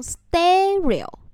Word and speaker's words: stereo: 那么呢，我们注意的是这个的音呢，它前面遇见一个死音，要变成的音去读，所以呢，它是读成 stereo 啊，重音符号在前面。